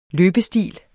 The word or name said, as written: løbestil